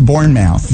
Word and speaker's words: Bournemouth: So let's go back to Bournemouth. – Yeah. – Did I say that right? – Yeah, it was beautiful.